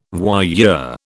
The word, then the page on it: voyeur: British English